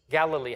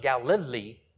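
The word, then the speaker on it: Galil (גליל): You can say it G-A-L-A-L. Galal. Where was Yeshua from? Galilee. How do you say Galilee in Hebrew? It's not Galilee, it's the Galil. Galil.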